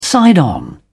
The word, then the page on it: side on: British English